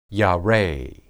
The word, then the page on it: yare: Hebrew